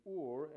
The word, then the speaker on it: Urim: "And you shall put in the breastplate of judgment the Ur and the Tum," and I'm sorry, I said earlier the way it's written here is Urim and Thummim, but again it's pronounced Ur and Tum in the Hebrew.